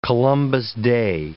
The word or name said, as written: Columbus Day